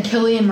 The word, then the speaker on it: Cillian: Killian Murphy. Guys, I'm Irish, and everyone always pronounces the names wrong.